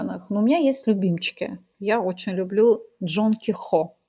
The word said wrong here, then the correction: Кехо: Джон Кехо или Кехо, правильное ударение.